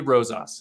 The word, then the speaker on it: Rosas: Andy Rosas.